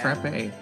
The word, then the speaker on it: Frappe: I'm pretty sure it's Frappe Snowland. – Frappe, yeah. – Frappe. Okay. Like the coffee thing.